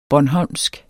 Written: bornholmsk

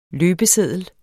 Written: løbeseddel

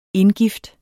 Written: indgifte

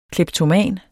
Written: KLEPTOMANS

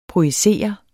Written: projicere

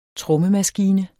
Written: trommemaskine

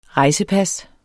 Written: rejsepas

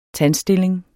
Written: tandstilling

